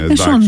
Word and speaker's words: Sean: Σον είναι η προφορά, τι να κάνουμε. – Συν εγώ το μέσα. Το λέγαμε λάθος εμείς. – Έτσι μεγάλωσα. Συν τον ήξερα τώρα.